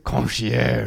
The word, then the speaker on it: concierge: Anyway, The concierge, am I saying that right? – Yeah. – She was like, I think it's concierge. – Concierge. – Yeah, yeah, yeah. Perfect.